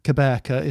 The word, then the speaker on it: Gqeberha: So he was born in South Africa in Port Elizabeth, which is now Gqeberha, is how it's spelt nowadays, or pronounced nowadays.